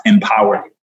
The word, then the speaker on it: EMPWR: And so it's EMPWR, and it's pronounced empower.